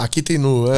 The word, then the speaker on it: Akita Inu: Uh, gostaria de saber se os cães de raças primitivas são de fato os mais difíceis de adestrar, como o Akita Inu. Eu falei certo? É isso? Akita Inu, é. Akita Inu.